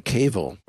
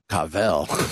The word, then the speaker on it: Cavill: But big news of the day as we're recording, Henry Cavill, or if you want to go the Marvel pronunciation, Cavill, like that? – Cute.